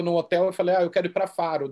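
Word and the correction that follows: Fårö: daí a mulher Farrow, Farrow da Fora, enfim, descobri como fala, pronuncia em sueco,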